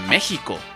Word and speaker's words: México: Bueno, el caso es que su pronunciación correcta, México, no México.